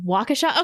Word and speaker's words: Waukesha: Just remember walk. Like walk to Waukesha.